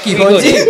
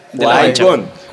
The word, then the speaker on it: Qui-Gon: Se lee Qui-Gon. – Y se pronuncia Qui-Gon.